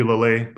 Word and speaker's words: Laleye: My full name's Adekambi Laleye, but everyone calls me Kambi.